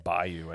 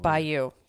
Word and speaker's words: bayou: Bayou, I believe. – Bayou. Thank you. Bayou.